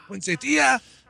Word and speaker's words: poinsettia: Poinsettia. – Poinsettia. Should be an accent on the next to last syllable.